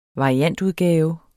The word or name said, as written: variantudgave